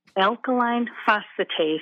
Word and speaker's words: alkaline phosphatase: I have an elevated alkaline phosphatase, and I don't even know if I'm pronouncing that correctly. – No, you are.